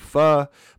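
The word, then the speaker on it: phở: So pho, P-H-O, pronounced pho, is a soup that comes from Vietnam, but has kind of made its way throughout Southeastern Asia.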